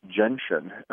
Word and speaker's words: gentian: It's called gentian. It's spelled G-E-N-T-I-A-N.